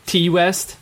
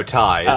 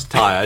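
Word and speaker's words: Ti: T West? Isn't that how you pronounce that? – No, Ty. It's Ty.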